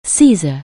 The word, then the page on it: Caesar: anglais